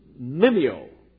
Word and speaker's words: mimeo: We would probably pronounce it, if we brought it over into our language, mimeo. M-I-M-E-O.